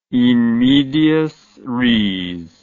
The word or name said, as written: in medias res